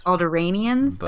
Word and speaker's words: Alderaanians: The Empire is rounding up fugitive Alderaanians. See, I need a... – I've always said Alderaanians. – Alderaanians? Okay. – Might be wrong. – Two A's, long A, Alderaanians.